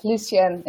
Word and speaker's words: Lucienne: Am I pronouncing your name properly? – Yes, Lucienne is it.